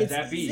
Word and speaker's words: Zappe: Yeah, Zappy. – It's Zappy? – Yeah. He's got an even goofier name. – That's not how you spell that. – No, that is how you spell it. It's Zappe.